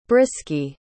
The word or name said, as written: brisky